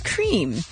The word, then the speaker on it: Creme: And then after everybody freaks out, somebody at Cadbury Cream Egg Twitter got the memo and they're like, oh, it's definitely pronounced as cream.